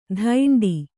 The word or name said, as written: ಧೈಂಡಿ